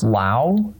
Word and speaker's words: Lao: But the first one is that I, as far as I can tell, it's pronounced Lao, like the name of the country. They don't say the S, or when people in the country say the S, they're just emphasizing it for foreigners so they can understand that.